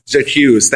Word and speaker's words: J'accuse: I don't know how to pronounce it in French, but it's like the letter J, which means like I. J'accuse, thank you.